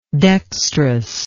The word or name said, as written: dexterousness